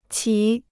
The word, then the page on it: 棋: qí